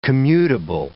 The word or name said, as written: commutable